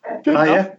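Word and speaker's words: Dedê: The main talking point was the sending off of the Cruzeiro centre-back Dede, or there is a Brazilian pronunciation of that, isn't there? I'm not quite sure how you pronounce it. – No, you got it. Dede. – Okay. I thought it was more of like a Gigi or something like that.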